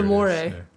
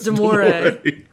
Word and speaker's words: Damore: Pretty sure Damore. Yeah. – When the moon hits your eye a big piece of pie. – That's Damore.